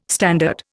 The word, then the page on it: standard: Indian